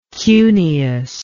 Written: cuneus

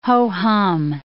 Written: ho-hum

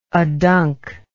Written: aduncity

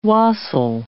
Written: wassail

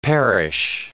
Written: perish

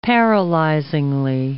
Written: paralyzingly

